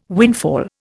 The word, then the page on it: windfall: Indian